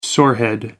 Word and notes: sorehead: American English

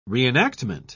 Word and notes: reenactment: British English

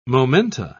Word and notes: momenta: British English

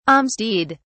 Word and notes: alms deed: British English

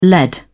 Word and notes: led: British English

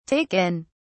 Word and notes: take in: British English

